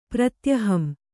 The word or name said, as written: ಪ್ರತ್ಯಹಂ